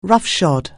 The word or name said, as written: roughshod